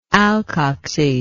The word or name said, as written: alkoxyl